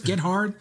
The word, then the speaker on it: Gethard: Is his name Chris Gethard? – Gethard. – Gethard. Chris Gethard. It's spelled Gethard.